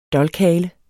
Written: DOLKHALER

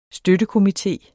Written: støttekomité

